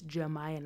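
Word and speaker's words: Jamaya: And it's Jemiah, not Jemiah, not Jemira.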